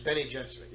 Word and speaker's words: Bene Gesserit: Bene Gesserit, you pronounce it? – Bene Gesserit, yeah.